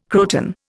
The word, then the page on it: croton: Indian